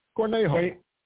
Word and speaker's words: Cornejo: So obviously, Mrs. Corhanyo, how do you pronounce her name? – Who cares? You're never going to hear from her again. Miss. Just call her Miss. You're never going to hear from her again. Cornejo.